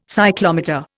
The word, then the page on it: cyclometer: Indian